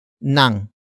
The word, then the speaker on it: ng: The next word is nang, which is spelled as ng.